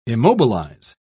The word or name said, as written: immobilize